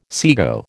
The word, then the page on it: sego: American English